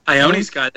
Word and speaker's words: Ione Skye: Is it Ione Skye? – Ione Skye. That's right.